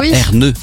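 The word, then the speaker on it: Ernő: Inventé par Ernaud Rubik. Erneux d'ailleurs, c'est comme ça qu'on devrait le prononcer.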